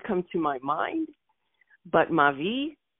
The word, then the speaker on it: Mavi: where my grandmother would ferment a beverage called Mavi. And we pronounced it M-A-V as in Victor, I.